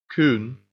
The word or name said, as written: coon